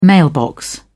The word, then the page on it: mailbox: British English